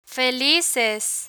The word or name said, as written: felices